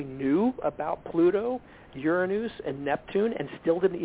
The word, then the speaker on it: Uranus: Western astrology completely destroyed counterparts because now we give Saturn to Capricorn, and then we give Uranus, that's the way you technically pronounce it, Uranus to Aquarius.